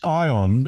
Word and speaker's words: Aion: It's ION. It's spelled A-I-O-N, as you say, but that's not some obscure Chinese cultural reference. It's a portmanteau, which is a word combining the spellings of and meanings of two words, AI for artificial intelligence and on for on the road.